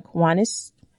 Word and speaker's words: Kiwanis: She is actively involved in the Kiwanis. Did I say that correctly? – Mm-hmm, correct.